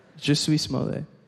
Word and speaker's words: Jussie Smollett: Jussie Smollett. That's how it's pronounced.